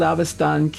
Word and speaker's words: Avestan: And he's also the author or translator of a recent publication of the Sacred Gathas of Zarathustra and the Old Avestan Canon.